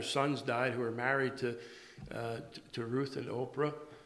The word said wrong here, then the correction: Orpah: Not Oprah. How do you say her name? Orpah, thank you. I do that. Looks like Oprah when you read it, don't?